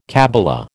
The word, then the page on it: kabbala: American English